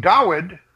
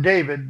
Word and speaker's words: Dawid: In the days of King Dawid, not David, they've changed the Vav into a wall, or a wall into a Vav, pardon me. They mispronounced that. It's Dawid with a W.